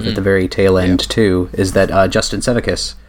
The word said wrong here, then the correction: Sevakis: Justin Sevakis, I said his name wrong. Justin Sevakis has started uploading the Jonathan Clements commentaries that were supposed to be included on the Blu-ray to his personal YouTube channel.